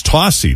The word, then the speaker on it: Tocci: You know, my family name is spelled T-O-C-C-I, and growing up, it was always Tossi.